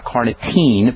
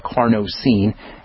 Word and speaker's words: carnosine: Zinc carnosine, not carnitine, but carnosine. And that's spelled C-A-R-N-O-S as in Sam.